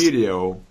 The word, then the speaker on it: video: We have two different vowels that come together. We separate them either with a hidden W or a hidden Y. Here, it's a hidden Y. Look, between the E and the O, there's a hidden Y. Three syllables. Video.